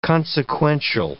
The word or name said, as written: consequential